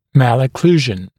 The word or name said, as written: malocclusion